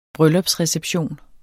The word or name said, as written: bryllupsreception